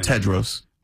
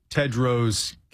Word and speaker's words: Tedros: Tedros. – Tedros Ghebreyesus.